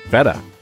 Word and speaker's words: feta: Ooh, feta. – Feta. Or feta. Depending. I hear it both ways. – Yeah. – I choose the American pronunciation with the American E. Feta. Feta is, I believe, more traditional.